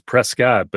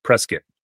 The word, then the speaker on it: Prescott: So I grew up in Arizona, and if you look at it, it's Prescott, but everybody in town, it's Prescott. – Yes. There you go. Exactly. – You go with the biscuit pronunciation.